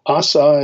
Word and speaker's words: Asa: Uh, and so the the next king that we're going to look at is a king by the name of uh, Asa or Asa, if you want to pronounce it uh, in its Hebrew form.